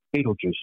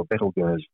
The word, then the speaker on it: Betelgeuse: At one end, you've got Rigel, and the other end, you've got Betelgeuse, or Betelgeuse, as it probably should be pronounced.